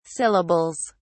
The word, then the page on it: syllables: British English